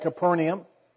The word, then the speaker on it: Capernaum: Most people pronounce that Capernaum, but when I was in Israel, the tour guide said it was Capernaum, the city of Nahum, and that's why it has stuck with me ever since.